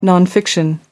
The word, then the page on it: non fiction: American English